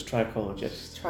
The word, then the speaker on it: trichologist: I've never known how to pronounce that word, so it's trichologist. – I call it trichologist, yeah.